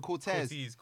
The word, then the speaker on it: Corteiz: It's a Corteiz.